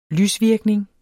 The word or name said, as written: lysvirkning